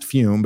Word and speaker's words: Fum: Remember, Fume is spelled F-U-M. It's pronounced Fume, but it's spelled F-U-M.